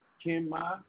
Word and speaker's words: Kim Ma: How do you spell it? K I M. – Yeah. – K I M. What's your last name? – M-A. – M-A.